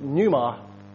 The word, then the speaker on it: pneuma: And when we turn to New Testament Greek, we find this word, pneuma, again, pronounced as so.